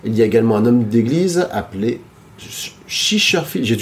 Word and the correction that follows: Chichester: j'ai beaucoup de mal à. Ouais, c'est ça. J'ai beaucoup de mal à prononcer son, nom Chichester. – Chichester. – Le Edward Chichester. – Chichester. C'est ça.